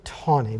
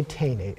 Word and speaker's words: Taney: After the, ju- the death of Chief, ju- Chief Justice Roger B., it's pronounced T A N E Y. I say Tawny, but other people say Taney. – Um, well, my father sailed on the U.S. Coast Guard cutter Roger B. Tawny during the Second World War, and he always pronounced it Tawny.